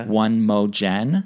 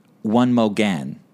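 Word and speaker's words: One Mo' Gen: One Mo Jen. – Oh, how is it? – One Mo Gen. – One like, Mo Gen. – One Mo Gen. Like one more again.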